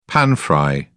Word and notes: pan-fry: British English

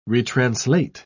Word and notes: retranslate: British English

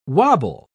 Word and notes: wabble: British English

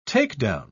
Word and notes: takedown: British English

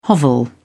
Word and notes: hovel: British English

